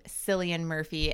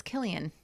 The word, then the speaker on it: Cillian: And I heard one of my friends pronounce his name Cillian Murphy, and I wanted to punch him. I was like, it's not Cillian, it's Killian.